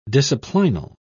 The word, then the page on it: disciplinal: British English